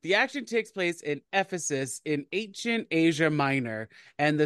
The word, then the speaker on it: Ephesus: The action takes place in Uffizi's. I said that wrong, didn't I? I knew I was going to say it wrong. How do you? – I mean, Ephesus. – Ephesus. I put the wrong emphasis.